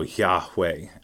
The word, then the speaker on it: Yahweh: Yeah, so the idea being that the name itself for Lord there, when you see capital, all uppercase, L-O-R-D, that's translation of Yahweh, basically. But the sound, the idea being that the name of God itself, Yahweh, when pronounced, is like the sound of breathing. So the breathe in sounds like Yah, and then the exhale sounds like way. So Yahweh.